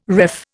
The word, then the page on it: ref: Indian